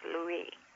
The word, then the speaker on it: Louis: Actually, I think it's pronounced Louis. – Louis.